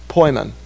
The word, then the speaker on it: poimen: Poimen. Yeah, it's the word for Ephesians 4:11 translated pastor. I think it's spelled P-O-I-M-E-N, right? Yeah, poimen. It's the only place it's a noun.